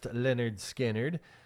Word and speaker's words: Lynyrd Skynyrd: So this was the day in 1973 that Lynyrd Skynyrd's debut album is released by MCA Records, pronounced Lynyrd Skynyrd, climbs to number 27 on the Billboard Top LPs and Tape chart.